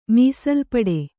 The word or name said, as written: ಮೀಸಲುಪಡೆ